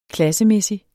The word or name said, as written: klassemæssig